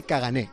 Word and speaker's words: caganer: Cagané, que he preguntado a un amigo y dice, no, se dice cagané. En catalán es cagané, la R no se pronuncia.